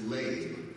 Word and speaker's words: lev: That word is pronounced lev, and it means the heart.